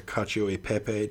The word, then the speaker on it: cacio e pepe: The cacio e pepe.